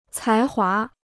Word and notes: 才华: cái huá

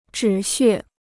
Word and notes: 止血: zhǐ xuè

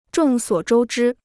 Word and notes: 众所周知: zhòng suǒ zhōu zhī

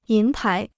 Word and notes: 银牌: yín pái

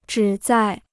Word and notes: 旨在: zhǐ zài

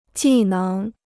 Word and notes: 技能: jì néng

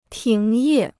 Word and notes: 停业: tíng yè

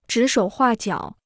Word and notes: 指手画脚: zhǐ shǒu huà jiǎo

